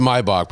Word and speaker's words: Maybach: It's pronounced Maybach, but everyone's going to say Maybach.